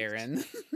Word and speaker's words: Aron: Is it Aron or Aron? How do you even pronounce it? Why is it eating ships? – Aron.